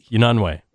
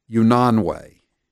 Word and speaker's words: Unanue: What's it, how does he pronounce his name again? – I can't picture it right now. Spell it for me. – UNANUE. – Unanwe. – Unanwe. Unanwe.